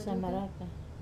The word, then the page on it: samaraka: Wamesa